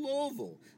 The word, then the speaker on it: Louisville: You're in Louisville, Kentucky. That's how we pronounce it.